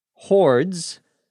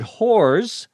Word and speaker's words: hordes: Hordes is a word that you need to pronounce carefully. You need to have the D at the end of hordes. Otherwise, you get the word whores, which means something very different.